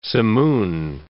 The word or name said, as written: simoon